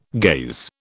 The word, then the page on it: gaze: British English